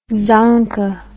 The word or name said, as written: dzongka